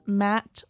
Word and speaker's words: Matte: Yes, and that's Matt, like Matt Black, like M-A-T-T-E. That's how you spell it.